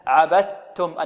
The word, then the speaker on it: عبدتم: وعندنا هنا الدال أدغمت في التاء إدغاما كاملا، عبدتم، النطق بتاء مشددة، نخرج من الباء إلى تاء مشددة، عبدت عبدتم عبدتم.